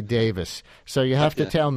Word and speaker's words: Davies: I think that the Welsh people who produced these names in the first place pronounce that Davis, even if it's got an E in it.